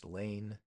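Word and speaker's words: Lahn: That's L-A-H-N, pronounced Lane.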